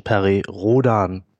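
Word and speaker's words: Perry Rhodan: Also die phonetische Aussprache, die oh. offizielle ist, ähm, obwohl Perioden ein amerikanischer Staatsbürger gewesen ist, da es eine deutsche Weltraumserie allerdings äh, ist, nennen wir den Menschen Perry Rodan.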